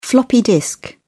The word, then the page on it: floppy disk: American English